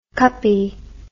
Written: cuppie

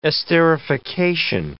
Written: esterification

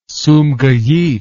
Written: sumgait

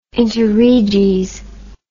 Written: interreges

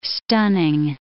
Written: stunning

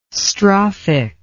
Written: strophic

